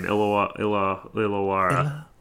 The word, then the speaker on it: Illawarra: Yes, that's a pretty cool name, Illawarra. – Well, I mean, I'm probably butchering it, so it's probably pronounced something completely different. – I think you nailed it.